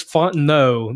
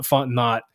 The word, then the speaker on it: Fontenot: And just to be clear, because I've had a couple people ask me and correct me on this, it is Fontenot, not Fontenot, because I actually said his name wrong several times on the previous podcast, so I've been saying it wrong this whole time.